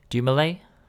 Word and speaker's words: Dumoulin: Dumoulin? – Yeah, you got it.